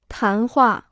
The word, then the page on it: 谈话: tán huà